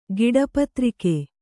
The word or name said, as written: ಗಿಡಪತ್ರಿಕೆ